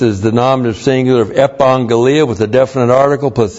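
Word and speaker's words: Epangelia (ἐπαγγελία): Epangelia. That's E-P-A, two gammas together. You're the first one, you pronounce it like an N-G-E-L-I-A. Epangelia.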